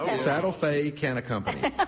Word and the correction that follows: Santa Fe Cattle Company: Santa Fe Cattle Company. I just said it right.